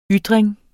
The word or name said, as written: YTRINGEN